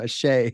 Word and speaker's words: Asé: I keep thinking ASE. So that's how I've been saying it this whole time. Ashe.